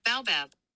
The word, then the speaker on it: baobab: Baobab.